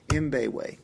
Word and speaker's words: Mbewe: Mbewe. – Yeah. – I've heard him introduce him as Mabiwi and Mabewe, yeah. And I think he kind of pronounces the M.